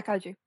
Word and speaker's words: Kaiju: Is it pronounced Kaiju? – Yeah, Kaiju. – Kaiju. Rob from Kaiju FM. Yeah, like the Pacific Rim film.